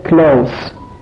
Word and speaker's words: close: اگر این کلمه اسم باشه به معنی نزدیک و تلفظ این میشه close.